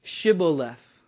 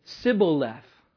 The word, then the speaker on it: Shibboleth: So say Shibboleth." And when these guys, the Ephraimites, would say that word, they wouldn't be able to pronounce the H. So they would say, Sibboleth.